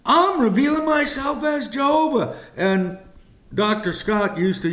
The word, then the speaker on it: Jehovah: Pronounced in our language, Jehovah, or in the Hebrew, it's pronounced Yahweh.